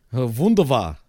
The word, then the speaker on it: Wunderbar: In German. Wunderbar. That's it. That's how you say it.